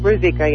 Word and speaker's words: Ruzicka: is that how you pronounce her last name? – Ruzicka, yes, correct.